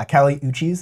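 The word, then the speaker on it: Kali Uchis: Yeah, Cali Uches is how I learned to pronounce that recently.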